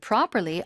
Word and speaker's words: properly: It's even more fun to do it properly on a real badminton court. – Do it properly. Pro의 앞에는 푸고요. 중간에 들어가는 P는 뿝니다. Properly. 쌍비유 발음.